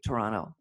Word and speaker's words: Toronto: So I would go to Toronto sometimes and I had clients in Toronto, you know, you swallow the tea, Toronto.